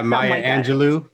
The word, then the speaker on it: Maya Angelou: Mary Angelou, I think. I don't know exactly how to say her name. I think it's A-N-G-L-E-U. – Yeah, Maya Angelou. Maya Angelou. – Maya, awesome. Thank you.